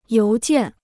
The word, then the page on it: 邮件: yóu jiàn